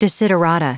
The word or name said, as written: desiderata